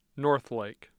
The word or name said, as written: northlake